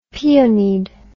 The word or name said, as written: peonied